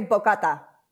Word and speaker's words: bocata: Or if it's easier, you can say bocata.